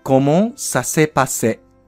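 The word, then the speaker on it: Comment ça s'est passé: 文字数は多いですが、発音は意外と短く、コモンさせパセ。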